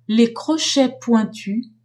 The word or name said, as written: crochets pointus